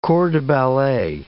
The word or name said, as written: corps de ballet